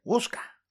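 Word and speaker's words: Whooshkaa: Pero se ha creado un respaldo en la web de hosting de audios que se escribe peor que se lee. – Wosca se escribe ahí. Pero se dice Wosca.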